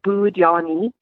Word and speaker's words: Budenny: So tell me how you say the name of this breed. – You kind of pronounce it Budenny. – Yay! Budenny. I've been correct, actually. I've been doing it right.